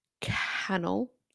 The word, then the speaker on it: Cannell: It's the urgent warning for all content creators, and it's by Sean Cannell, rhymes with channel, which is what he likes to say.